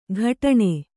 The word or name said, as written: ಕಠಾಣೆ